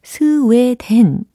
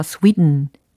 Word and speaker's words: Sweden: 그런데 과연 영어적으로는 어떻게 발음이 되느냐? 바로 이겁니다. Sweden, Sweden, Sweden. 오, 좀 다르죠? Sweden, Sweden. 아, 특히 이 끝부분의 소리 들으셨어요? 물론 모음도 스웨가 아니라 스위 위 이렇게 들렸으면서 동시에 된 이게 아니라 음 이렇게 약간 글로털 사운드라고 하거든요.